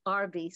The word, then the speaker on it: Arbe: I spell it A-U-D-R-Y-E. If you need my last name, it's Arbe, spelled A-R-B-E.